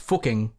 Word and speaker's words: Fucking: It's actually pronounced fucking, like book, like booking with an F.